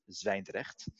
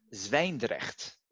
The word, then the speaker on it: Zwijndrecht: I'll pronounce it Zwijndrecht. Kind of difficult, difficult word. Z W Y with two dots. N D R E C H T.